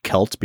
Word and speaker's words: Celt: Now, Brad, I am just going to go ahead and assume that it's pronounced Celt because it's, you know, it's Celtic. – Yeah, like the Boston Celtics.